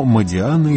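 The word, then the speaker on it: Модиано: Во Франции, разумеется, ставят ударение на последний слог, но Модиана – итальянская фамилия.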